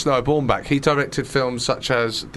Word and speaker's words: Baumbach: Noah Baumbach.